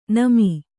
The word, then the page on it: ನೆಮ್ಮು: nemmu